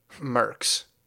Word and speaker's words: Merckx: Enter Edouard Luis Joseph Baron Merckx, rhymes with Irks, a.k.a.